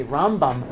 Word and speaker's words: Rambam: So the way to remember it is we say Rambam, Maimonides, with the emphasis on the first syllable, and Ramban, with Nachmanides, with the emphasis on the second syllable.